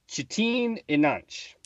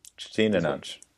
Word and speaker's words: Çetin İnanç: Uh, but we you reached out to the internet to find out how to correctly pronounce the director of Turkish Star Wars, and we think close enough. Çetin İnanç. – Çetin İnanç is what we're going with for the director of this uh, cult uh, oddity.